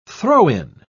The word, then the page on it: throw-in: British English